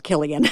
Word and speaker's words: Cillian: So that's a hard C on. So it's Cillian.